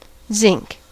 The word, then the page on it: zinc: American English; /zɪŋk/